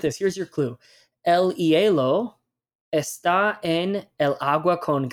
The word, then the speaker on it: hielo: Number two, el hielo. El hielo. And this one has my favorite letter, H, at the beginning, H, which is silent in Spanish.